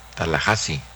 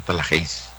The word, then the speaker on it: Tallahassee: Se pronuncia Tallahassee, no Tallahassee. Tú lo seréis.